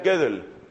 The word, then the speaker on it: Gödel: Hay que leer lo que estudian los matemáticos a partir de un hombre llamado Kurt, con K-U-R-T, Kurt Gödel. Gödel se escribe G-O-D-E-L. La O lleva diéresis y se pronuncia Gödel.